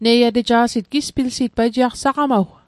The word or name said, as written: saqamaw